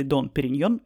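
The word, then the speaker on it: Dom Pérignon: Дом или Дон – это обращение к священнослужителю, поэтому оба варианта произношения названия шампанского «Дом» или «Дон Периньон» верны рассказывает,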